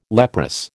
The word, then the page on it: leprous: American English